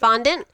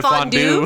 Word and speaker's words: fondant: Fondant. Yeah. People try to like fondue. No, fondue is cheese.